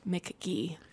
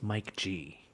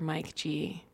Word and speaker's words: Mike G: You know, I just learned this week that his name is not pronounced Mick Gee. – I'm pretty sure it's Mike G.